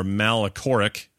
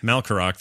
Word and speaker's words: Malkorok: Alas, there is no way for Malkorok. I don't think I say his name. – Malkorok. – Malkorok, thank you, to create precisely 3.7 implosions.